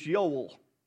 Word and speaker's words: Yoel: His name in Hebrew is Yoel, with a Y, you would pronounce it.